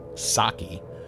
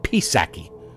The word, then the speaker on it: Psaki: For those of you new to the program, I understand she pronounces her name Saki, but it's P S A K I, and I choose to mispronounce her name Pisaki because it's more appropriate.